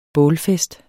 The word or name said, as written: bålfest